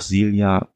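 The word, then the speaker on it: Zealia: Und Mirko, du hast ja auch gesagt, bei HP Podcraft zum Beispiel wird auch Silja gesagt, ne, so dass wir davon ausgehen, dass das hier auch die korrekte Aussprache ist.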